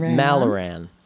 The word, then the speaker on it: Maloran: Am I saying that right? – Maloran. – You know, mm-hmm. I was in between the two, and I was like, I'm going to go. Okay. – I know. It's tricky.